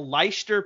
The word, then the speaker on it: Leicester: So he played for the Leicester Panthers, and he actually did pretty well.